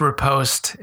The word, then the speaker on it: riposte: If it lights up, then you can hit riposte. You have to have enough rage or focus to do it. – I was just going to say it's pronounced riposte as you were saying, which is correct.